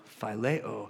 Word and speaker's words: phileo: It's Philadelphia, Phileo. The root of the word the book is written is brotherly love, phileo. And if you look into the pronunciation, it is phileo.